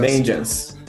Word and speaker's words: Vengeance: Que é o Truffle Ninja, que no dia 15 lançou o seu álbum novo, Vingance, Vingance, sei lá como é que pronuncia essa porra. Mas é isso daí, a Vingança. Vengeance. Vengeance.